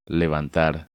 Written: Levantar

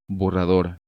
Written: Borrador

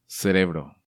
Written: Cerebro

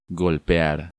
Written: Golpear

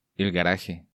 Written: El Garaje

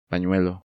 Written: Panuelo

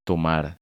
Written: Tomar

